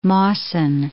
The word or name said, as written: Mawson